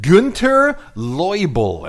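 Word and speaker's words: Günter Loibl: Well, there's this guy named Günter Leubel, and I am pronouncing that correctly because I saw an interview with him on YouTube, and the guy looked at him and said, "So it's Günter Leubel?" And he said, "Yeah."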